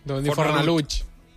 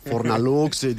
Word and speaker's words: Fornalutx: Tot anava a dir, Fornalutx no deu ser fàcil de pronunciar ni pas castellano parlants de la península, no? Fornalutx. – Deu dir Fornalutx. – Perquè entre aquesta X final...